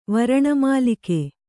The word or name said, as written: ವರಣಮಾಲಿಕೆ